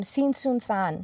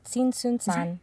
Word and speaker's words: Tzintzuntzan: And I love this name, Tsintsunsan. – Yeah. And it rolls off the tongue. – It really does. And we should put it in our notes too, because it's spelled like Tzintzuntzan.